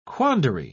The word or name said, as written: quandary